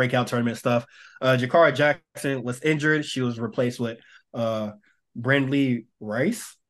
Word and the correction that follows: Brendley Reese: Brendley Rice. – It's Reese. It's definitely Reese. It's Brendley Reese, yes.